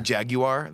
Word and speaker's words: Jaguar: Jaguar? That's how the Brits say it. That's how they say it over the pond.